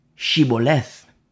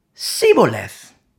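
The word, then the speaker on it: Shibboleth: If he said no, verse 6, then they would say to him, then say, Shiboleth. And he would say, Siboleth. For he could not pronounce it right.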